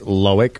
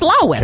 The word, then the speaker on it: Loic: All right, let's see if it actually is Loic or if it's Lois.